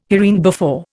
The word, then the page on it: hereinbefore: Indian